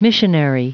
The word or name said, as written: missionary